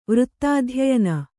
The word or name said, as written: ವೃತ್ತಾಧ್ಯಯನ